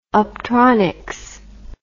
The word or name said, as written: optronics